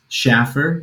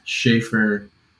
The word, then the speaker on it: Shaffer: Because we go make reservations anywhere, and I just tell them it's Shaffer because that's what it looks like. But it has always been pronounced Shaffer where I'm from. So I need to put the line above the A from now on, see if that works.